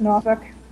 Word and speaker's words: Norfolk: Okay, and am I pronouncing Norfolk correctly? – Yeah, it's fine. It's fine. – Norfolk. Is it Norfolk? Is that how the natives pronounce it? – That's Norfolk, yeah. – Yeah, because I would pronounce it Norfolk. – That's what I did. I did that when I first got here because I was fresh out of New York, and I was quickly corrected.